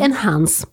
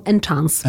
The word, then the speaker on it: enhance: Czyli enhance, a nie najtość boską enchance.